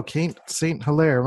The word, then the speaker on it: Kate St. Hilaire: Okay, welcome to the show, Kate St. Hilaire.